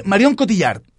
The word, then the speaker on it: Marion Cotillard: Marianne Cotillón. – Marion Cotillard. – Marion Cotillard. – Marion Cotillard, estábamos confundidos con la sílaba.